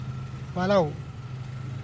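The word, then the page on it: belau: Teotitlán del Valle Zapotec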